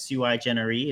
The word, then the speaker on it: sui generis: So that's Sui Generis is really the pronunciation in Latin.